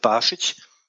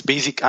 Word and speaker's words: Basic: Nur wenn man sich zwei Beispiele anguckt, so von mit den erfolgreichen Blogs, die wir so kennen, das ist von Robert Barschic, Robert, ausgesprochen, viele sprechen das als Basic an, der hatte einen Mischblock, der hat ja über alles damals geschrieben, bis er das verkauft hat, was ihm unter die Finger kam.